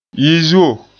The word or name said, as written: yizuo